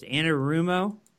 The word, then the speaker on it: Anarumo: Anarumo. – You didn't butcher it. You nailed it.